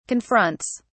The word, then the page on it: confronts: British English